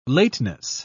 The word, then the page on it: lateness: British English